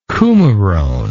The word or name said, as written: coumarone